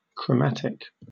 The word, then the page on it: chromatic: British English